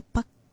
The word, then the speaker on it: pakkana: There, pakkana has a double k. So please make sure that you pronounce it well. Of course, there is a focus and a slight stress on the double k there as well. So pakkana. Kana. That's how you do that.